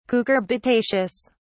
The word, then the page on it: cucurbitaceous: British English